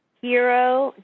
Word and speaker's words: Jiro: It's called Hero Dreams of Sushi. – Wait a second. – Hero. – What part did you not know how to pronounce there? – Because it's spelled J-I-R-O.